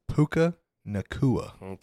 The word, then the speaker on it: Puka Nacua: Say his name slower. – Puka Nacua. – Okay, there we go.